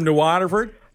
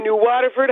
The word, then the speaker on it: New Waterford: He's my new Waterford hotness. – Yeah, there you see, you're even saying it right. It's, you get people from away, as I call them, New Waterford. No, it's New Waterford.